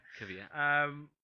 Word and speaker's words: Kvyat: You know, Felipe Massa then took seventh, followed by Toro Rosso's Carlos Sainz and Daniel Kvyat. Am I saying that right? Kvyat. – Kvyat.